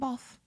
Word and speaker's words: Balfe: Did you say her name, Bolf? – Yeah.